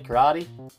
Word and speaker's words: karate: But, yeah, in Australia you say Karate. But, it's actually pronounced Karate. Karate. Japanese spoken language operates on the vowels, on a syllable and a vowel. So it's K-A-R-A-T-E.